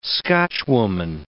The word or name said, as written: Scotchwoman